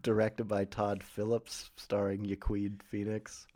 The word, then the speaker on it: Joaquin: I pronounce it Joaquin,